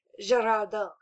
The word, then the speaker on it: Girardeau: We open up on Cape Girardeau, Missouri.